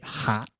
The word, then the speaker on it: hot: A bit hot. – That's right. – The correct pronunciation is an oh sound there. Hot, by the way. – In British pronunciation, but in American, it's hot, hot, open, hot.